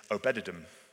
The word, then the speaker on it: Obed-Edom: And he dumps it in the house of this guy nearby, Obed-Edom. Although I did hear someone this week pronounce it as Obed-Edom, which I quite liked.